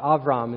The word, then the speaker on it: Avram: Well, in today's scripture, we're going to zero in on that plan and look at one man named Abram, or Avram is how it's pronounced, but for sake of our anglicized versions, I'll just say Abram, or refer to him as Abraham from here on out.